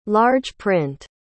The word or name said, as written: large print